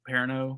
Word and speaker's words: Perrineau: The only part I read about it was early on with Harold Perrineau.